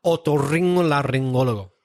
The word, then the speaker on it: otorrinolaringólogo: Otorrinolaringólogo. I'm really proud of that. – Wow. Otorringo? – Otorrinolaringólogo.